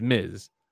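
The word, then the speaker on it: Ms.: No, there's Mrs., there's Miss, M-I-S-S, and then there's Miz, M-S, period.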